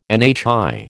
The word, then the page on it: NHI: American English